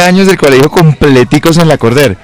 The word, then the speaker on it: Lacordaire: Lacordaire. – Pero como en francés, como Lacordaire. Sí. Una cosa así. – Es que es un nombre francés. Sí, se escribe Lacordaire, pero se pronuncia Lacordaire.